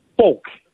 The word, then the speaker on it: Folk: Uh, tell me the pronunciation of your last name. I don't want to screw your name up again. Is, it folk yeah, or folk? It, it, it's folk. Folk, like folks.